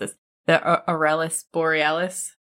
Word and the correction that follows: Aurora Borealis: What is it? – Aurora? – The Aurora. I knew I was going to mess that one up. The Aurora Borealis.